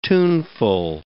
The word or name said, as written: tuneful